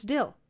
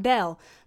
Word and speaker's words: Dill: It's Dill. It's not Dell. The way they pronounce it when they have their accent, Dill sounds like Dale, but it's Dill. It's Dill.